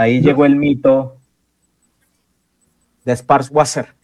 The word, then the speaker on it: Sparwasser: Sparwasser.